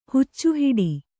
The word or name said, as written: ಹುಚ್ಚುಹಿಡಿ